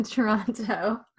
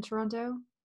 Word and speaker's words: Toronto: Just, just uh, uh, people keep pointing out to me that I pronounce all the T's in Toronto, and it like freaks them out. well, yeah, you're not actually from Toronto, then.